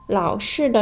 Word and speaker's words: 老式: 以前只能打电话、发信息的手机，在现在就可以说成是老式手机了。需要注意的是，老式的“ 式” 是四声，声调不同，意思也就不一样了。